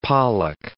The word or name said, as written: pollock